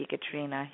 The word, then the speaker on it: Ekaterina: So that just my first name at Ekaterina.